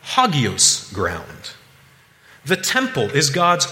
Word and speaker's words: hagios: Now, I'm no Greek scholar, but when I click the link and the little speaker to hear what the word is, it's pronounced hagios.